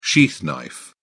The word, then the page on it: sheath knife: British English